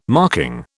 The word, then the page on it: marking: British English